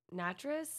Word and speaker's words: Natras: So there's this guy, Joseph Natras. – Right. – Who rhymes with mattress.